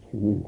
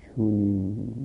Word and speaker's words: چنین: چیه به کسره تلفظ میکنن، لهجه چنین هست، لهجه چنین چنین هست لهجه ما، نه چونین چونین.